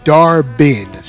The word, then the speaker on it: Dar-Benn: Sorry, I'll, I'll pronounce it right. It was Dar-Benn.